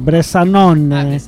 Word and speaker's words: Bresanone: Bresanone.